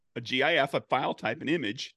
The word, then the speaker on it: GIF: Oh, I'm a GIF. Okay. Always have been a GIF. Always will be GIF. Because it's graphic is the first word. So GUH, it's hard G sound.